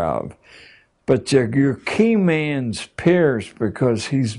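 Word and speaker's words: Peirce: He was, it's pronounced Pierce, but it's spelled as if it were Pierce.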